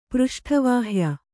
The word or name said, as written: ಪೃಷ್ಠವಾಹ್ಯ